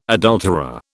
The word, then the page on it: adulterer: British English